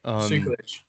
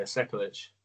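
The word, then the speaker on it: Sekulić: Dwight Grant versus Stefan Sekukic. I don't think I said that right. – Sekulic.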